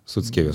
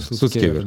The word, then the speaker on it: Суцкевер: Да сами, э, там тот же самый Илья Слуцкевер, Слуцкер, правильно говорю ему Суцкевер. – Суцкевер, да.